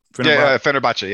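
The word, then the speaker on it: Fenerbahçe: I know what I see when I watch her overseas for Fenerbahce. How do you pronounce it? – Fenerbahce, yeah.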